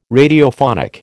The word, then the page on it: Radiophonic: American English